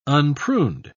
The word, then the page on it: unpruned: British English